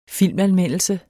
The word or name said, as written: filmanmeldelse